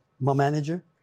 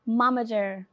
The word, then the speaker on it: momager: Is it mom manager? – Momager.